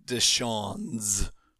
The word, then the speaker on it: Deshaun's: Let's move on to Deshaun and Dragons. Is that okay, Bob? Can I pronounce that all right? – It's technically Deshaun's with an S, but otherwise, yeah, that's fine.